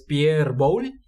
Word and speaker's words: Pierre Boulle: Es Pierre Boulle, tú lo podrás pronunciar en un francés más adecuado. Pierre Boulle.